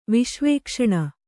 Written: ವಿಶ್ವೇಕ್ಷಣ

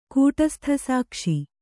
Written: ಕೂಟಸ್ಥಸಾಕ್ಷಿ

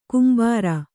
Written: ಗಂಭೀರ

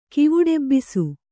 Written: ಕಿವುಡೆಬ್ಬಿಸು

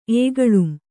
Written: ಏಗಳುಂ

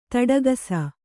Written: ತೊಡಕಿಸು

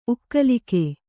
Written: ಉಕ್ಕಲಿಕೆ

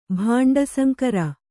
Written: ಭಾಂಡಸಂಕರ

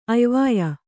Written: ಅಯ್ವಾಯ್

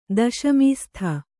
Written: ದಶಮೀಸ್ಥ

